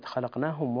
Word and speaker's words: خلقناهم: كلمة خلقناهم، رقق اللام فيها، ونبهنا أحبتي الكرام إلى هذا الموضع سابقا، اللام في كلمة خلقناهم وقعت هي مرققة ووقعت بين مفخمين، ومثل هذه المواضع تحتاج إلى عناية في النطق وتأن حتى تخرج الحروف سليمة صحيحة فصيحة. نقول خلا، خلقناهم، خلقناهم، ولا نقول خ لا لا، لا نفخم لم، خلقناهم.